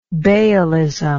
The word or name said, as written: baalitical